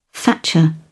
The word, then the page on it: thatcher: British English